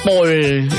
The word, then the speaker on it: Paul: ¿Paul se escribe, se pronuncia Paul? – Sí. – Paul, ¿no es cierto? – Exactamente.